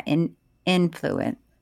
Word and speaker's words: influence: Yeah, influence. I can't tell which syllable to hit. Influence. – Well, it's like, influence. You know, the word insurance. I say insurance.